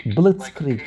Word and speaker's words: Blitzkrieg: Blitzkrieg? Is that how you pronounce it? – Blitzkrieg. Like a lightning strike.